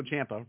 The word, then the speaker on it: Ciampa: But question, Tommaso Ciampa, rhymes with Tampa, is arguably the greatest NXT wrestler of all time.